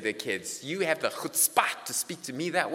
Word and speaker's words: chutzpah: Chutzpah. That's actually the right way to pronounce it. I listened to a whole podcast by Malcolm Gladwell. It's not chutzpah. It's chutzpah.